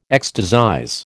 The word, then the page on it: ecstasize: American English